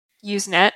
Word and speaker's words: Usenet: So, moving along from Usenet, am I pronouncing it correctly? – Yes.